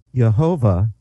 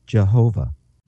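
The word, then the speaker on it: Yehovah (יהוה): Now the second word, which is translated Lord, is pronounced Yehovah, as we would say Jehovah, which means the self-existent or eternal one.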